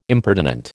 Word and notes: impertinent: American English

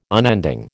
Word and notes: unending: American English

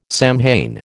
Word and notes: Samhain: American English

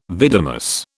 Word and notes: vidimus: British English

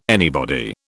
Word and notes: anybody: British English

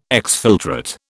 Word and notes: exfiltrate: British English

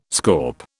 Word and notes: scaup: British English

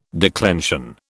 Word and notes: declension: British English

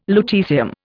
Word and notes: lutecium: Indian

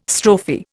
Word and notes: strophe: Indian

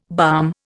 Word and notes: balm: Indian

